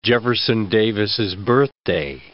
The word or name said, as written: Jefferson Davis's Birthday